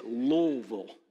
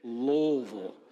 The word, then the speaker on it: Louisville: This is the proper way to say it, Louisville. Everybody say it, Louisville. Yeah, it's not Louisville, it's not Louisville, stop it. Right? You guys need to stop it. That is not appropriate. You say it like you have rocks in your mouth.